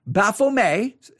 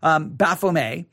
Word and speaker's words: Baphomet: The picture showed a statue of, and I'm going to spell it for you, B-A-P-H-O-M-E-T. Baphomet, the T is usually not pronounced, it's a French word, Baphomet. But sometimes in America, you'll hear it pronounced Baphomet, but it's Baphomet.